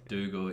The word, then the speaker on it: Dougal: Dougal is like a hard name to pronounce, and nobody has it. I get a lot of Dougal. Dougal. um, I just say it's Google with a D.